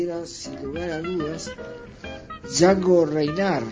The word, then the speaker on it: Django Reinhardt: o como le dicen la mayoría de la gente, Django Reinhardt, pero la pronunciación de Django Reinhardt la tomé del gran Rodríguez Tabeira, que aparte me parece mucho más acertada esa pronunciación que la pronunciación inglesa o americana, ya que él era belga y vivía en Francia.